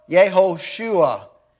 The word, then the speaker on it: Yehoshua: Jesus in Greek, equivalent to Joshua in the Old Testament, or Yehoshua, it is pronounced in Hebrew.